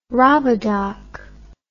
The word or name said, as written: robodoc